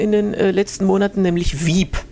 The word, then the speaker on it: Veep: Veep, kurz zur Erklärung, sind die Buchstaben V und P. Veep, oder sozusagen spitznamisch Veep ausgesprochen.